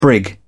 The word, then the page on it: brig: British English